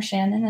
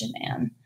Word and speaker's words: Shanann: In the documentary, they like call her Shannon and it bugs me because I know that's not how you say it. – Oh. – It's Shanann.